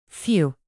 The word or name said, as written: few